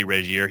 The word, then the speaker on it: Regier: I say Regier. He keeps correcting me. It's Regier, R-E-G-I-E-R.